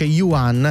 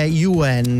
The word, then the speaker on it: Yuan: Sì, perché si dice italianizzato. – La moneta cinese. – Esatto, si dice Yuan. – Yuan. – Ma la pronuncia in cinese mandarino è Yuan.